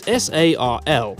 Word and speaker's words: SARL: The most common structure is SARL, pronounced S-A-R-L in French.